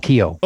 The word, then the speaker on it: Keough: It's Noel Keough.